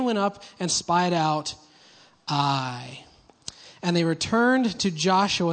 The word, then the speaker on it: Ai: All right, so they just had this massive victory in the city of Jericho, and they go to a place called Ai. Say Ai. Now, in your Bible, it will be Ai, but it can be pronounced Ai.